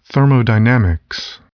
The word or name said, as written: thermodynamics